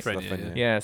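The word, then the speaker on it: Lafrenière: I'm very curious to see this new first overall pick, Lafreniere. Am I saying that right? – Lafreniere.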